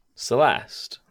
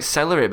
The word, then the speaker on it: Celeste: Lee Holmes says something that I can't pronounce. – Celeste. – Yeah, Celeste, that's it. – How would you have tried to say that? – I was thinking more like celery, but I wasn't sure if it were right.